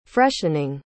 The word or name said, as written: freshening